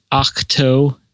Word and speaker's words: Ahch-To: Yeah, but the planet that they're on is called Ahch-To, A-H-C-H-T-O.